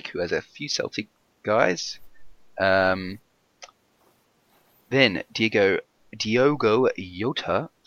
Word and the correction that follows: Jota: that's definitely not how you pronounce it. – Jota. – Is it? See, my Portuguese isn't up to scratch, I told you. – Yeah, yeah. Portuguese is easy. You think of Jose Mourinho, you actually pronounce the J.